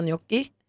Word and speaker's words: gnocchi: Fast det är rätt att säga gnocchi.